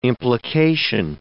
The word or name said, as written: implication